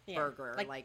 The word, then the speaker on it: Berger: Now, I know it's spelled B-E-R, but it's actually pronounced Berger. – Like the burger.